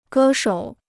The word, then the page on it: 歌手: gē shǒu